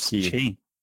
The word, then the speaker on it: Qi: We have to get back to Doc saying Q charger. What do you mean Q? You mean Qi chargers? – Qi.